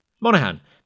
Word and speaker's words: Monaghan: And we have Tommy Monaghan. Monaghan? Monaghan. The G is silent.